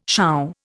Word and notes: shaw: Indian

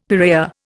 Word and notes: piraya: Indian

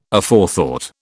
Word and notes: Aforethought: British English

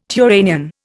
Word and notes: Turanian: Indian

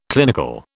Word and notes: clinical: British English

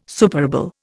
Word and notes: superable: Indian

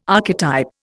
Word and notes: archetype: Indian

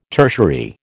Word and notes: tertiary: American English

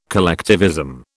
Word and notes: collectivism: British English

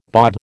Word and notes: bawd: American English

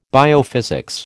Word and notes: biophysics: American English

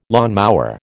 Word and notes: lawnmower: American English

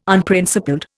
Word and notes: Unprincipled: Indian

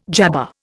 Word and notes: jabber: Indian

